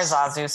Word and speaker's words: Zazu: Bar Zazu.